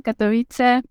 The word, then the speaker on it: Katowice: no sé si lo he pronunciado bien. Pero eh, bueno, pues quiero darle la bienvenida aquí al podcast porque hoy nos va a contar su historia con el español. ¿Qué tal? Buenos días, ¿cómo estás? – Hola, buenos días. Muchísimas gracias por invitarme y tener esta oportunidad de contar mi historia en tu podcast, que como dijiste, sigo y me gusta mucho. Eh, sí, pronunciaste muy bien, Katowice, Polonia, Sur.